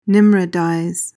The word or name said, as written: nimrodize